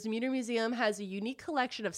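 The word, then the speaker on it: Mütter: Now, you pronounced it correctly, the Muter Museum. – I did pronounce it No, correctly. I'm saying you did, but a lot of people call it the Mutter Museum. – Both are fine.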